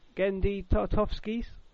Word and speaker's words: Genndy Tartakovsky: They are doing their first, like, original commission, and that is bringing back Genndy Tartakovsky's. Hey, is that right? – Yeah, I think you're pronouncing it right.